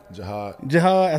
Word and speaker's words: Jihaad: Jihaad.